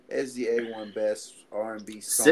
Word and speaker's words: SZA: Before we continue, SZA. It's like the Wu-Tang Clan. RZA, the Jizza. It's just that syllable and Zuh.